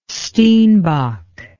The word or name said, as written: steenbok